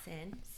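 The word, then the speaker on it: Seine: The Sai? – The Sin.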